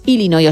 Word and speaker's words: Illinois: Pero es Illinois, o sea, si tú lo pronuncias bien y tienes buena dicción, es Illinois. – Illinois. – Luego, si lo pronuncias rápido, según el acento, como decía Beto también, pues te sale una medio E, pero nunca un Illinois.